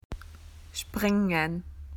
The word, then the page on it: to jump: Australian English